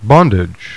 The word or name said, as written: bondage